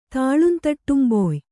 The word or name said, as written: ತಾಳುಂತಟ್ಟುಂಬೊಯ್